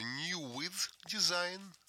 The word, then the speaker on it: New With Design: Вот, кстати, как правильно произносится? New With Design.